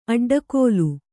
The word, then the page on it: ಅಡ್ಡಗೆಲ್ಲು: Kannada